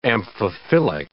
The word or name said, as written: amphiphilic